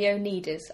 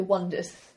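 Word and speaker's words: Oneders: Originally it's spelled O-N-E-D-E-R, as in the one... Mm-hmm. But no one could pronounce it, so they changed it to The Wonders, spelled correctly. They're brilliant.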